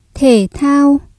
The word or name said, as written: thể thao